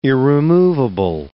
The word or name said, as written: irremovable